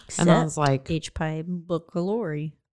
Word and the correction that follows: H. pylori: You added a syllable. – What is it? – H. pylori.